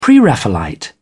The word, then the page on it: pre-raphaelite: British English